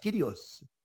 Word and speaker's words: kurios: He is telling you the Spirit is kurios, kurios. Depending on how you want to pronounce it,